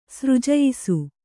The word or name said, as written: ಸೃಜಯಿಸು